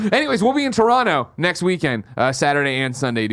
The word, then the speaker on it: Toronto: Basically, a week from tomorrow, me, Nick, and Andy are headed over to Toronto. We're going to get in there. You got to drop. That's, they say Toronto weird. We say it Toronto. They're like Toronto.